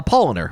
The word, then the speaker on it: Polaner: Polaner.